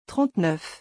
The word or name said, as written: 39